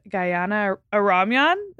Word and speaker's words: Gayane Aramyan: I am sitting here with Gayane Aramyan.